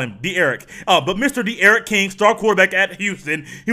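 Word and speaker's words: D'Eriq: his name Derek King. There are different ways to spell a name that pronounces one way. No, it's not Derek King, it's D-Eric King.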